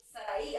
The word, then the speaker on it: Sarahi: Now I tell people Sarahi. I don't care whether you're white. – It's Sarahi. – You're Asian, black, Indian. I'm telling you, my name is Sarahi. You're gonna say it like that.